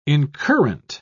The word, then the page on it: incurrent: British English